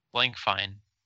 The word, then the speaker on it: Blankfein: Did you say Blankfein? – I did say Blankfein. You said Blankfein. Like Stein.